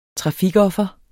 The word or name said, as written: trafikoffer